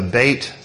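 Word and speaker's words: Bet (בית): Some of you may have it spelled B-E-T-H at the top, but that's actually not the way you pronounce it. That is, well, okay. There's two ways of pronouncing Hebrew. There's the classical, which would be Bet, but modern Hebrew actually pronounces this letter Bet.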